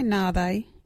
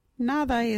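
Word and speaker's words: nhaabayi: You can say Nabe in this sentence. Where is the Nabe? Or, you know, Nabe. Nabe is spelt NHAABAY I.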